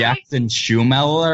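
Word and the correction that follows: Schmoeller: or whatever, however you pronounce his name. John Schumeller, who's the director of Tourist Trap. – Schmoller. – Yeah, Schmoller, yeah.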